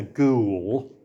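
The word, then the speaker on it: Goole: There's an Inspector Ghoul, who is meant to sound like G-H-O-U-L, but it's spelled G-O-O-L-E, I think, because it's a bit creepy what goes on.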